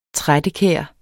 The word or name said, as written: trættekær